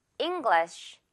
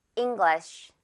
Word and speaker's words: English: English. 마지막에 살짝 쉬, 발음으로 끝내주세요. 자, 갑니다. English.